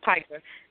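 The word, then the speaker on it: pica: Pika. Okay. – It's Pika. – Pika, thank you.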